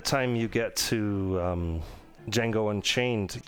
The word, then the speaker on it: Django: Django. D J A N G O. The D is silent moving up to one of his newest ones, which is Once Upon a Time in Hollywood, you get to that mm-hmm.